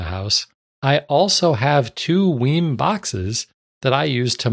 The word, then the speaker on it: WiiM: He's referencing the story that we talked about last episode about the Weim amp. I'm still going with Weim as the pronunciation of W-I-I-M, capital M for some reason.